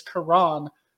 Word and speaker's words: Karan: It's the emphasis is on the second syllable. So his first name is Karan.